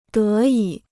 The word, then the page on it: 得以: dé yǐ